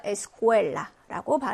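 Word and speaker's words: escuela: 3번도 역시 모음으로 끝났습니다. 그죠? 그래서 끝에서 두 번째 음절에 강세가 와서 escuela라고 발음해 주시면 되고.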